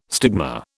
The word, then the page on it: stigma: British English